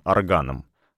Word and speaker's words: орган: Тоже тепло, уют и невесомость — и тихое гудение то ли воды, то ли ванны, то ли водопроводных труб, потихоньку подрабатывающих органом с ударением на втором слоге.